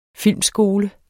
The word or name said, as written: filmskole